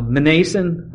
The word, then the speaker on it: Mnason: You have to pronounce the M and the N together. How do you do that? I'm not really sure. Mnason?